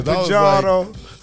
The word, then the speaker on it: Parjado: Yes, you said that right. Some people say Parjado.